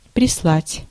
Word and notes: прислать: /prʲɪˈslatʲ/; prislat

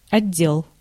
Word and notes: отдел: /ɐˈdʲːel/

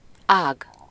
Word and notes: rameau: [ʁa.mo]

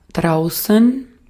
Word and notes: draußen: /ˈdʁaʊsən/

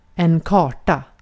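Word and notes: karta: IPA: /ˈkɑːrta/; IPA: [ˈkʰɑːʈa]; IPA: /kɑːʈa/; Swedish

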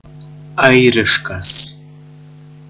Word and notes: irlandais: France; [iʁ.lɑ̃.dɛ]

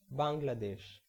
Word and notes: Bangladesh: /ˌbæŋ.ɡlə.ˈdɛʃ/; [ˌbæŋ.ɡlə.ˈdɛʃ]

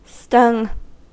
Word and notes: stung: American English

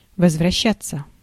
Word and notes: возвращаться: /vəzvrɐˈɕːætːsə/; vozvraštšatsja

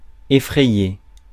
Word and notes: effrayer: France; [e.fʁɛ.je]

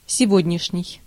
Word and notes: сегодняшний: /sʲɪˈvodʲnʲɪʂnʲɪj/

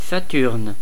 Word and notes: Saturne: [sa.tyʁn]

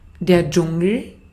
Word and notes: Dschungel: [ˈdʒʊŋl̩]; /ˈd͡ʒʊŋəl/; [ˈd͡ʒʊŋl̩]

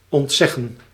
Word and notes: ontzeggen: /ɔntˈsɛxə(n)/

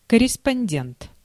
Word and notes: корреспондент: [kə.rʲis.pʌnʲ.ˈdʲent]

